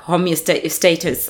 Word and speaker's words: homeostasis: Like, I I just never, like, I just never went into uh, homeostasis, uh, like that's how you pronounce it, where I never went into balance.